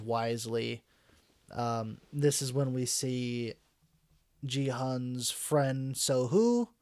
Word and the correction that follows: Sang-woo: right? So-hoo, if I'm not mistaken. Right? That's his name? – Sang-woo? – I thought it was Sun something. – Sang-woo? – Is that his name? Spell it. – S-A-N-G. – Okay, so Sang-woo.